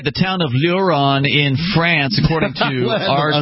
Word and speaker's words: Lieuron: It's spelled L-I-E-U-R-O-N. Luron. – Oh, I heard Huron.